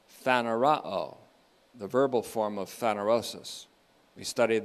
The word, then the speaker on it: phanerosis: But there's a word that is similar to it, and it can even pass as a synonym for it, and that's phanerosis, P H A N E R, long O S I S, phanerosis.